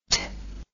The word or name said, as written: tch